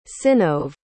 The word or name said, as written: synnove